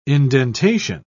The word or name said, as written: indentation